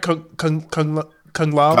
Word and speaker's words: Kung Lao: I was like, if I see this scene one more time, and then they never show Kung Lung. Am I saying it right? Kung Lao.